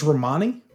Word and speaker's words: Romany: Uh, I don't know how uh, Romany Malco is that. I'm not sure if that's how you say his name. – I think it's Romani. Romani.